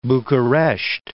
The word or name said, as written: Bucuresti